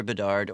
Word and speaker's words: Bedard: So you could either look it up by my name, Peter Bedard, or you could go to Convergence Healing, and you'll find the book on there. – And Bedard is spelled B-E-D-A-R-D. So bed like what you sleep in, A-R-D.